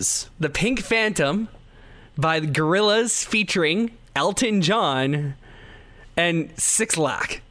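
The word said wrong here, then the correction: 6LACK: It's actually pronounced Black.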